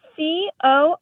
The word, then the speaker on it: cornswaggled: CORNSWAGGLED. – Interesting. Are you saying corn swaggle or corn swoggled? – We say it like corn swaggled.